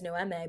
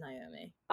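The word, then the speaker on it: Noemí: So I was reading it as Noemi, but then I typed it into Google Translate and I put it in like Mexican, and then I clicked it. But the little accent on the I turns it into Naomi.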